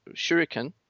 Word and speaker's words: shuriken: How do you pronounce shuriken? – Shuriken? – Yeah. – Yeah, that's how you say it.